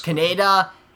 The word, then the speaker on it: Kaneda: And it was the same issue with the, is it Kaneda? How do you say Kaneda his name? Is the guy with the red bike, and then Tetsuo is... – Kaneda and Tetsuo, their rivalry friendship.